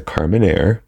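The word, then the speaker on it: Carmenère: Because before I think we said like Carmenere or something like that, or Carmenere. – Ah. – But it's a Carmenere.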